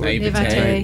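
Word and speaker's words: naïveté: You know, people want to sell something, so they say it's better than it is, and especially in my naivety. How do you say that? – Naivete.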